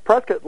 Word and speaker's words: Prescott: How do you pronounce that? – Prescott, like in biscuit.